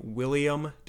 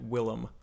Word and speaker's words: Willem: if I didn't mention the fact that you pronounce his name William Defoe when it's in fact Willem.